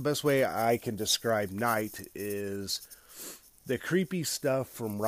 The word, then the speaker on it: Nigght: I don't know, a day or two ago, whatever, but they're called Night, but they spell it NIGGHT.